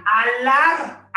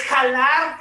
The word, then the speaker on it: jalar: La J es como la H, es muda. Entonces, imagínate escuchar la palabra alar. Alen, voy a alar esto, lo aló. O sea, es jalar, jalen, jaló.